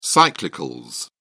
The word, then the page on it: cyclicals: British English